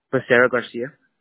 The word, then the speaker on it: Becerra Garcia: Becerra Garcia. – How do you spell that? – B-E-C-E-R-R-A, Garcia, okay. – G-A-R-C-I-A.